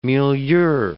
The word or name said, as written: milieu